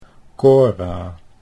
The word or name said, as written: Cora